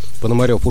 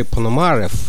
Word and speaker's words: Пономарев: Благодаря работе у Блейки имя Валери Пономарев, американцы произносят эту фамилию с ударением на «а», на долгие годы стало единственным русским именем, известным широким кругам любителей джаза в США.